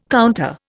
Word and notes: counter: Indian

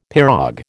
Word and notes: pirogue: American English

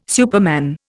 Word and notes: superman: Indian

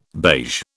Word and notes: beige: British English

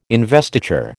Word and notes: investiture: American English

